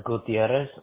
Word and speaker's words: Gutierrez: Last name is Gutierrez, which is spelled G-U-T-I-E-R-R-E-Z.